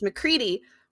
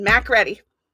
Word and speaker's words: MacCready: and Mac's name is MacCready, but the way that it's spelled every time I was reading the subtitles, I said MacReady. – MacReady. – In my head. – You too. – MacReady. – MacReady. – That's funny. It really bothered me, and I was like, you're so fucking stupid. It's not MacReady.